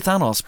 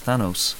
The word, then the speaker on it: Thanos: I'd say Thanos, but the official pronunciation now is Thanos.